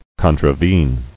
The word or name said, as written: Contravening